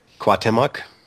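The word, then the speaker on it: Cuauhtémoc: Cuauhtemoc. – It's Cuauhtemoc. That's how you pronounce it.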